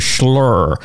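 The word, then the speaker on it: Shloer: And fair play to Jamie from the Tote for instantly knowing how to pronounce it. That's the sign of a long-time listener, first-time caller. It's really called the Schlurr.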